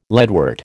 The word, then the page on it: leadwort: American English